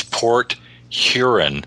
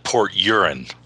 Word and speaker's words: Port Huron: And remember to pronounce it correctly. It's Port Huron, not Port Urine.